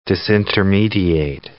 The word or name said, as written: disintermediate